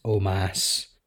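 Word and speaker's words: Omos: So next up we have Omas, which apparently is pronounced Omas, versus Bobby Lashley.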